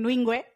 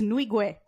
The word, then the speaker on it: Nwigwe: I keep saying Nwigwe. It's Nwigwe. I can't do it.